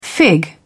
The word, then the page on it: fig: British English